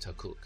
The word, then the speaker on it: Takkuuk: called Takuk, spelt T-A-K-K-U-U-K, and pronounced Takuk.